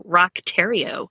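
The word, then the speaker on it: Roch Thériault: his name is spelled Roche Theriault. That's how I Okay. – would phonetically sound it out. But I listened to I watched an investigative discovery with like video on it, like an hour-long video. And they called him Rock Terrio.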